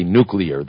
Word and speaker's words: nuclear: And I did say nuclear. That is the correct pronunciation. It seems to be a hell of a lot of experts that still say it like George W. Bush.